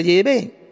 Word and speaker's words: Yahvé: Bueno, quiero decir también que he sustituido el nombre Jehová, el nombre falso Jehová, por el nombre Yahvé, ya que esta es la correcta pronunciación del nombre del Dios Padre y de su Hijo Jesucristo, en idioma hebreo arcaico.